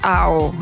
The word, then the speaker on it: Ao: Il et elle disent que ça se prononce comme miaou à l'anglaise, mais sans le mi. Donc Ao.